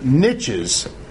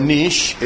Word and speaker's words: niche: What we say is that these different species occupy different niches. Or if you prefer niche, it can be pronounced either way, niche or niche.